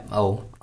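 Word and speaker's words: Ou: It's spelled O-U, correct? – Yes. – Okay. So I didn't know if there was another tail to it.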